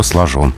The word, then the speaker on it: сложен: чувствует язык, очень хорошо знает норму и правильно отметил, правильно зацепился за это сочетание, потому что там неправильное ударение. Правильно, мужчина хорошо сложен.